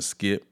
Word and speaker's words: Skypp: You know, I go by the name of Skypp. That's spelled S-K-Y-P-P.